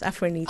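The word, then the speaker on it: Afronita: So, Afronito it's pronounced, isn't it? – Yes, Afronito.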